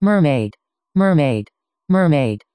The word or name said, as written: mermaid